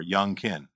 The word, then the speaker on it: Youngkin: Glenn Youngkin. – I want to pronounce it like Young, like Carl Jung. – Just think of him as a rapper, Youngkin.